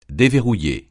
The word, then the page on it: déverrouiller: francais